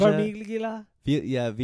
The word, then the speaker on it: Farmiga: Farmiga? – Farmiga.